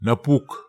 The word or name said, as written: napuk